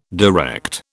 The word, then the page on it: direct: British English